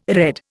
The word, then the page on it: redd: Indian